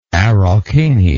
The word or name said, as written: Araucania